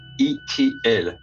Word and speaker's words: Itiel: Extraterrestrials will just add the letter L, so it's pronounced E-T-L.